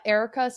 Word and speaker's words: Erika: Guess what Erica spelled with a K means?